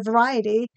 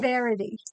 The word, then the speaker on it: variety: And I just want to say that one of the words Chris mispronounces every single time is the word variety, which is why I didn't put that in the notes under his section. You are welcome. He says verity every time.